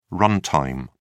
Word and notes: run-time: British English

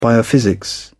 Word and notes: biophysics: British English

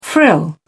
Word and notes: frill: British English

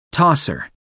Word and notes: tosser: American English

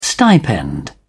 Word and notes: stipend: British English

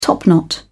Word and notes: topknot: British English

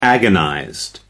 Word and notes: agonized: American English

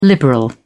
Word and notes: liberal: British English

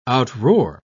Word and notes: outroar: British English

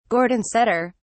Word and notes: gordon setter: British English

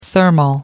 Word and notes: thermal: American English

